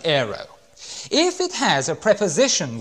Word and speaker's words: airo: And the word is the word aero. I better spell it for you because you're going to spell it wrong otherwise. It's not a chocolate bar. A-I-R-O. And it's the word aero. A-I-R-O.